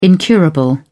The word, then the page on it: incurable: British English